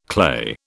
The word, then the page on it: clay: British English